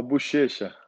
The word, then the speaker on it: Buchecha: Did I pronounce it right? Buchecha? Buqueca? I think it's Buqueca, right? That sounds better. – No, Buchecha.